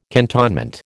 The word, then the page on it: cantonment: American English